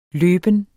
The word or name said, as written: løben